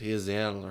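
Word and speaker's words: Gisele: It's G-H-I... – Isn't that Tom Brady's wife? – I don't know. – Giselle? – It's not Giselle because the G was silent. They kept pronouncing it Hizelle or something like that.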